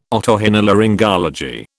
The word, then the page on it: otorhinolaryngology: British English